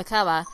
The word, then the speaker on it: Kaaba: Yeah, the Kaaba.